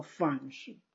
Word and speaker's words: 饭食: 经又说要饭食精行，有人误以为在西方极乐世界吃饱事儿没事了，走走玩玩啊，其实大错。哎，那肯定，我们现在师兄弟肯定都知道了。嗯，饭食应读作饭食啊，只修道吃饱了法食，就应精行十方，反过来给众生吃法食。